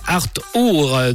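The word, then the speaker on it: acht Uhr: Or, certains Français ne respectaient pas cette règle et les patrouilles criaient au retardataire Arthur, 8h en allemand, qui se prononce donc Arthur d'un ton menaçant. Se faire appeler Arthur, qui devient vite euh, par euh, déduction Arthur,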